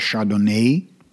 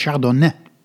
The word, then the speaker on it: Chardonnay: De Engelsen rekken dat woord nog wat uit naar Chardonnay. Maar ik ben wel eens gecorrigeerd door een Fransman die zegt: het is Chardonnay.